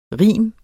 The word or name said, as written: rim